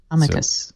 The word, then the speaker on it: amicus: Amicus. – So the K is, the C is hard, but the emphasis is on the first syllable.